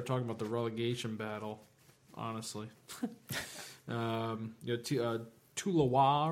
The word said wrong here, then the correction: Toulouse: or whatever you pronounce it, definitely hack that up. Toulouse.